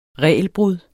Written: regelbrud

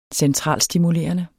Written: centralstimulerende

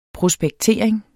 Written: prospektering

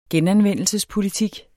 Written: genanvendelse

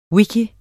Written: wiki

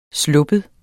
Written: slap

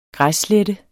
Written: græsslette

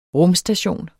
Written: rumstation

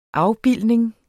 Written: afbildning

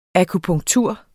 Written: akupunktur

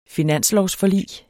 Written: finanslovsforlig

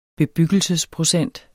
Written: bebyggelsesprocent

